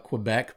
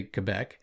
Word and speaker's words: Quebec: We would say in the States, we would call it Quebec, but they do say Quebec. And I know that only because I looked it up, because I wanted to say it right.